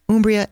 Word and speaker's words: Umbria: Umbria it is.